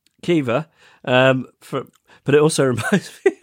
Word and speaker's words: Caoimhe: Would it be possible to get a podication for my niece, Kiva? I read ahead because you taught me how to pronounce it because it's... guessing it's an Irish name. – I think so, yeah. – C-A-O-I-M-H-E.